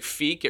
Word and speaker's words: Feek: Like, Feek, it rhymes with unique.